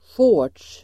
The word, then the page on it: shorts: [sjå:r_ts]